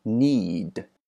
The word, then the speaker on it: knead: 第三个单词呢是一个及物动词 Knead，这个 k 是不发音的。